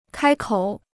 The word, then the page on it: 开口: kāi kǒu